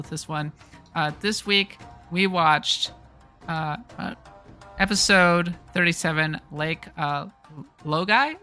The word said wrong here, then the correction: Laogai: Is that how it's pronounced? – Laogai. Lake Laogai.